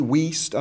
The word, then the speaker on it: Wuest: All right, now, the Weest translation, and it's spelt W-U-E-S-T, Weest.